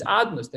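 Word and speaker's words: Adonai: We pronounce it as Adnus, the name of Aleph. Adnus, Aleph and Dalet and Nun and Yud.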